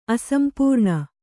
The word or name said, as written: ಅಸಂಪೂರ್ಣ